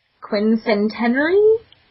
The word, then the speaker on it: quincentenary: how do you say this? Quincentenary? – That's pretty good.